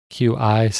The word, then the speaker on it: Qi: that QI standard, it's pronounced Qi. Richard's not saying it wrong.